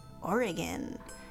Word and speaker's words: Oregon: It's pronounced Oregon.